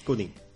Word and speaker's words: Cooney: Passem, passem a la següent obra, es diu Taxi, és la comèdia més exitosa de Ray Coney, o Cooney. – Cooney.